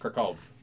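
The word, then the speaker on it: Kraków: In Polish, it's pronounced Krakow.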